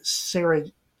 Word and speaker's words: Sarajevo: So the spark that ignited World War One was in Sarajevo, Bosnia? I believe the J is pronounced as a Y,